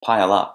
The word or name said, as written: pile up